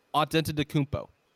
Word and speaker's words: Antetokounmpo: There's been reports that Giannis Antetokounmpo, he's upset. He will be upset if the Bucks are not able to. What was that last name? – Antetokounmpo.